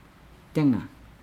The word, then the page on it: denga: Nukuoro